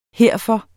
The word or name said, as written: herfor